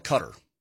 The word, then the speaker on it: Qatar: Let's say we want to call it cutter. – Yeah. – You say C-U-T-T-E-R. – Correct, cutter. That's exactly right. You spell it phonetically. Qatar would be, you know, little K-A dash capital T-A-R, Qatar.